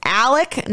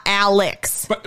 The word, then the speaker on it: Alex: The father's name is Alec, not Alex, it is spelled. – But it's spelled with an X. – It is spelled Alex Murdaugh. It is pronounced Alec Murdaugh. – That's a completely different name. You can't just change your name. – I don't. – The Murdaugh one I kind of get, but Alex, like with an X and you pronounce it Alec, is wild.